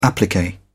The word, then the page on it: applique: British English